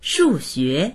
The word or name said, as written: shu4xue2